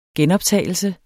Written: genoptagelse

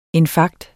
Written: INFARKT

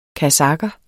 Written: kasakher

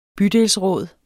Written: bydelsråd

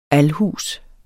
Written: alhus

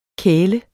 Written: kæle

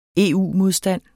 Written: EU-modstand

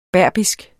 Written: berberisk

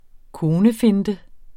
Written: konefinte

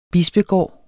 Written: bispegård

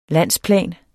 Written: landsplan

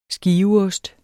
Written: skiveost

